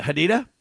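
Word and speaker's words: Hodeidah: And Saudi airstrikes hit supply lines around, how do you say it, Hodeida.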